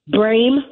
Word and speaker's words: Brame: It's WINRED, WINRED, Brame, my last name, BRAME, for Congress.